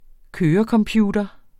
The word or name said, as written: kørecomputer